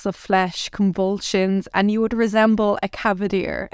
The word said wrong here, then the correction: cadaver: How do you say that? A dead body. – Cadaver. – There we go.